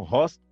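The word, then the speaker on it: Reuss: Depois ele encontrou com o Franz Hartmann, encontrou com o Rost, que se escreve Reus, né?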